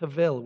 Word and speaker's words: hevel: Now, it's got a B. That's the way that the Hebrew word exists, but it's pronounced hevel with a V sound.